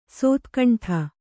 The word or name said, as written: ಶತಖಂಡ